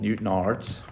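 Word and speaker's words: Newtownards: But in short, you're from a small town in Northern Ireland called Newton Ards. Have I pronounced that correctly? – You have pronounced it right, yeah.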